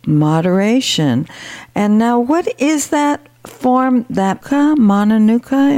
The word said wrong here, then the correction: Manuka: Manuka honey.